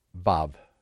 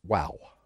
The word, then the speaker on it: Vav: An Englishman that learns Hebrew pronounces it the way a German does, Vav, V-A-V. Americans pronounce it Wow, W-A-W, which is correct, both of them, either one of them.